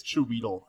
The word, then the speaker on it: Chiwetel: I did Chiwetel Ejiofor. – Oh. – If I said that correctly. – You didn't, but I couldn't. – I know. – I think it's Chiwetel, actually.